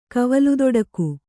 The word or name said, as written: ಕವಲುದೊಡಕು